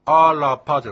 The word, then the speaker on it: アラパ: ですから、アラパじゃなくて、アラパというような感じで、 R ですから飲み込む感じですね。 – あ、ラとしっかり発音しない感じなんですね。 – そうですね。 – アラパ、アラパ。アラパというのが、アイヌ語としては、正しい発音でありますね。はい。